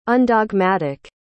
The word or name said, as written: undogmatic